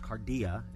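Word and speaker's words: kardia: Because, see, throughout the Bible, when Jesus says, love the Lord your God with all your heart, the word for heart, a word that is pronounced cardia in Scripture, encompasses the physical, emotional, and spiritual life of human beings.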